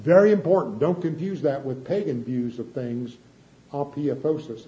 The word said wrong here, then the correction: apotheosis: I'm not saying it right, where the spirit gets glorified. This is talking about resurrection. Different concept. – Apotheosis. – Thank you, Larry. Every time I say it wrong, and I have to ask that Larry to help me say it right.